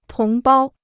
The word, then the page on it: 同胞: tóng bāo